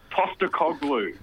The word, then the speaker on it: Postecoglou: So, Astapoglou, is that right? – Postecoglou.